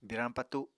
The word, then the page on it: birañ patu: Apatani